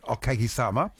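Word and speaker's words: Okagisama (お陰様): So firstly, the phrase is Okagasama. I'm learning how to pronounce it, Okagisama.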